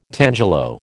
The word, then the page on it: tangelo: American English